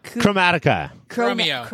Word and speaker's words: Chromatica: Chromatica.